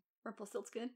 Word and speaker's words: Rumpelstiltskin: And then the next scene, they go to a dungeon and they meet Rumpelstiltskin. Or is that how you, is that how you say his name? Rumpelstiltskin, yeah.